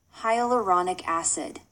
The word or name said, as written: hyaluronic acid